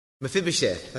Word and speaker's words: Mephibosheth: Mephibosheth, that's it.